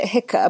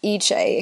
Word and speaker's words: Hicce: It's spelt H-I-C-C-E. So I always call it Hicker, but it's actually pronounced Eche in Coldrop's Yard.